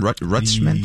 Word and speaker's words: Rutschman: Adley Rutschman, is that how you pronounce his name? Rutschman? – Yes.